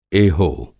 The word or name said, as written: एहो